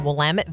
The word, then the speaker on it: Willamette: But it's Willamette. Rhymes with damn it. And they actually have bumper stickers in Oregon. It'll just say, it's Willamette, damn it.